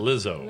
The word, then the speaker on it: Lizzo: Lizzo? – Is that how he says it? Lizzo. – Lizzo. Okay. – L-I-Z-Z-O.